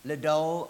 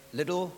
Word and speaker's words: Liddell: But this verse reminds me of a man named Eric Liddell, or Eric Liddell, I think it's pronounced here, Eric Liddell.